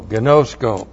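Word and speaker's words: gnosko (γινώσκω): G-I-N long O-S-K long O. Gnosko.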